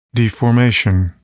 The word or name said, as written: deformation